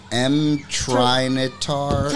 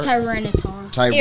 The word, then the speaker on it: Tyranitar: This is M-Trinitar? – Tyranitar.